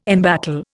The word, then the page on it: embattle: Indian